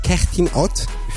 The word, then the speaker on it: Kerstin Ott: C'est une chanson de la chanteuse et compositrice allemande Kerstin Ott.